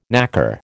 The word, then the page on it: naker: American English